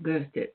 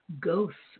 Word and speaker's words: Goethe: Maybe they say Goethe or something like this, pronounce it the English way, but it's Goethe, G-O-E-T-H-E.